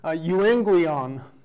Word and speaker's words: euangelion: If you're taking notes, it's a Greek word. It's pronounced euangelion.